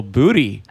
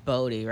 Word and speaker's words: Booty: We were laughing about because her last name is pronounced Body. – Right. – But people say... – Well, actually, it's pronounced Bodie, right? – Yeah, Bodie. – Like Point Break.